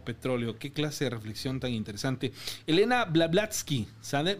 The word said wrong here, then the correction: Blavatsky: Madame Blavatsky.